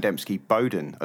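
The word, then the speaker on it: Dembski-Bowden: Oh, man, we're looking at the majesty, the pinnacle of Black Library writing, which is, well, anything by Aaron Debsky Bowden. Or. Yes Aaron Dembski Bowden, as I heard someone call him today. So I think I've been saying his surname wrong.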